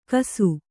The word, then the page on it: ಕಸು: kannada